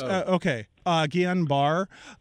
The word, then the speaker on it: Guillain-Barré: But is it Guillain-Barré syndrome? – Mm-hmm. Yeah. – Guillain-Barré, I think. But yeah. – Yes. Guillain-Barré syndrome.